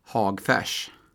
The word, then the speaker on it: Hagfors: Han är från Hagfors, va? – Ja, Hagfors, va? – Ja, hur uttalar man det när man är därifrån? – Hagfärs.